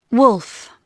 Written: wolf

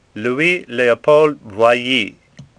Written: Boilly

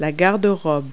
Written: closet

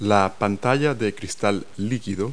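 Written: LCD screen

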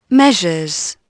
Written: measures

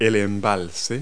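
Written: reservoir

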